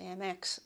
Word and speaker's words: Mx: Mix, I think, is how it's pronounced. I think I tend to say MX, and often it can be quite difficult to get these things changed on bureaucratic forms and medical forms.